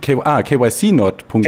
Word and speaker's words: KYCnot.me: Und hier die Webseite, wie spricht man das aus? Qnot, KYCnot.me. – KYCnotme. – Okay. Ich habe versucht, das als Wort zu lesen.